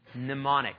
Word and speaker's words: mnemonic: The first thing is remember, and the word remember is mnemoneo, mnemonic. That's one of those funny words that has an M in front of the N, but you don't pronounce the M.